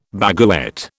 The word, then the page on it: baguette: British English